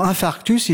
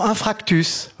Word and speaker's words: infarctus: Comme par exemple infarctus. Hein, pour infarctus, ils diront infractus, voilà.